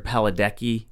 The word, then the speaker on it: Padalecki: I remember Jared Padalecki.